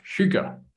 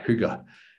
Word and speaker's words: hygge: Okay, and the last one is, have you ever heard of this concept of hygge? So it's not English, as you can tell from the pronunciation. It's Danish. Okay, it's, it's all over the news right now. – Everyone's talking about hygge.